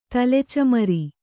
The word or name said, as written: ತಲೆಚಮರ